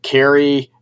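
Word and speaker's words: Khary: Kerry, it's K H A R Y, so it's spelled a little bit different.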